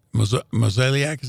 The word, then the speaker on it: Mozeliak: is because Mozeliak, is that the way you say his name? – Yeah.